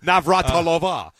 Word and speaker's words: Navratilova: But, I saw John McEnroe and Martina Navratilova. – Nice. – I said that right? – Kind of. Navratilova.